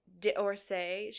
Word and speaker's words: D'Orsay: Brooke Dorsey, D'Orsay. She's got a little God's comment in there. It's like a D apostrophe.